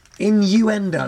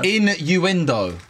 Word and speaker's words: Innuendo: In-du-ingo. – So close! – In-du-ingo. – Innuendo. – Innuendo. – Yes. – Yeah, got it right.